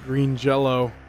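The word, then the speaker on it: Green Jellÿ: So this is interesting that the band's name is spelled out Green Jelly, but there's an umlaut on the Y, so you pronounce it Green Jello.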